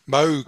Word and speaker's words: Moog: And you've got your Moog t-shirt on tonight as well. Did I pronounce that correctly? – Moog.